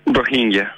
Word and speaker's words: Rohingya: Tienes muchos premios, uno el premio Polk de fotografía por tu trabajo sobre la situación de los, ¿cómo se pronuncia? ¿Rohingya? – Rohingya.